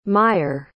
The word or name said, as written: meyer